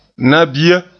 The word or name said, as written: nabia